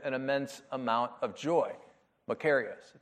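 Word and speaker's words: makarios (μακάριος): So there is this Greek word that appears in the New Testament that is pronounced makarios, and it's translated in our New Testament as blessed or blessed.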